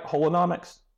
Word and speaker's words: Holonomics: Did I say that right? Holonomics? – Yeah, Holonomics. Yep.